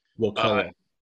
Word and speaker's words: Wakulla: Wakulla.